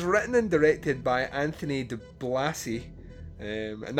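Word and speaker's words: DiBlasi: De Blasi is how you pronounce his surname. – Okay. – It's D-I-B-L-A-S-I, de Blasi.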